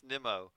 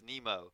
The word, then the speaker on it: Nimmo: Nimmo, I should say. It's pronounced Nimmo, according to him. His pronunciation guide says Nimmo.